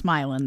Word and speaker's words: Milan: And yes, I know it's spelled Milan. – Yeah, because well, I said it was Milan too. – This is Indiana, so it's pronounced Milan there.